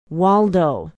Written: waldo

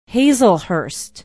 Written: hazelhurst

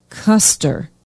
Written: Custer

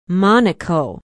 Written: Monico

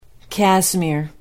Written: casimir

